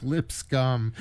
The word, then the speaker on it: Lipscomb: And then lastly, we wrap things up with something from Maurice Lipscomb, and It sounds like Lipscomb. Hey, you need chapstick for that. But anyway, it's spelled L-I-P-S-C-O-M-B.